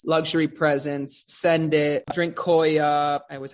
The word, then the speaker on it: Koya: How do you say that? – Koya, Drinks Koya, which looks phenomenal.